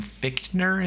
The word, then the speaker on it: Fichtner: He's played by, I had to look this up, William Fichtner is how it's pronounced. – Mm-hmm. – Yeah. – Fichtner.